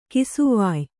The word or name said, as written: ಕಿಸಿವಾಯ